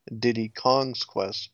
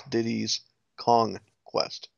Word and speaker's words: Diddy's Kong Quest: And then a lot of people mispronounce the name because a lot of people think it's Diddy Kong's Quest, but it's Diddy's Kong Quest.